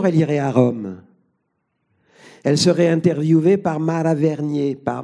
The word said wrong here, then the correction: Venier: Elle serait interviewée par Mara Venier à la Vita Indiretta.